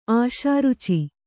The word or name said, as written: ಆಶಾರುಚಿ